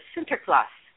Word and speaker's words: Sinterklaas: The Dutch pronunciation of St. Nicholas is Sinterklaas,